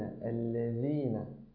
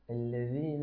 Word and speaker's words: الذين: الذين الذين الذين أنعمت عليهم. حاول أن تخرج طرف لسانك بقدر بسيط وليس بقدر كبير لكي تتمكن من نطق حرف الذال كما يمكن.